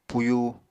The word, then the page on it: puyu: apatani